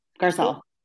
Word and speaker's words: Garcelle: Garcella? Garcella? – Nope, Garcelle, Garcelle.